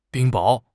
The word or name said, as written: 冰雹